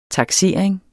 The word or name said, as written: TAKSERING